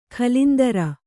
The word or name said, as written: ಕುಲೇಂದ್ರ